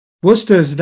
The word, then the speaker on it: Worcester: Worcester doesn't look like, it doesn't spell anything like that, but I'm proud to say you don't have to tell me how to spell that one. – You pronounce it correctly. Most people don't.